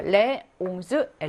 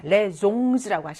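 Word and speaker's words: les onze: 레 옹즈 엘레브라고 합니다. 레 종즈라고 하시면 안 돼요.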